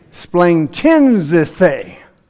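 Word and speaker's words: splanchnizomai: The first syllable is splang. Say splang. Splang chinzithe. I was full of splang chinzithe.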